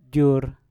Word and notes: jur: Mpur

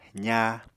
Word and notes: zaa: apatani

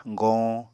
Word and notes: goñ: Apatani

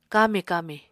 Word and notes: kami-kami: Apatani

